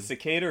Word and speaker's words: cicada: Is it cicada or cicada? – Cicada.